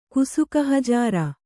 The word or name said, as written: ಕುಸುಕಹಜಾರ